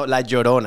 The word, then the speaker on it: La Llorona: No, La Llorona, I think you're saying. – Yeah, is that how you say it? See? – Because it's two L's.